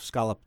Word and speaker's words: scallop: Maybe there is a type of scallop that, I'm saying scallop because that's how they say it in Canada, and I'm Canadian now.